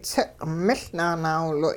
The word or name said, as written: tsiq'-miL nanaawiloy'